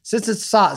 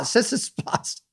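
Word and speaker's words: Tsitsipas: I had tied to Djokovic, so I had like three parlays remaining, and he was a minus three forty five favorite over Sitsipas. Sits, how, how do I say that? Why didn't I? I had it right. Sitsipas. Sitsipas. – Don't That's get ridiculous.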